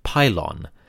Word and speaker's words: pylon: In the Egyptian context, we pronounce it pylon, and it refers to the monumental gateways of the Egyptian temples.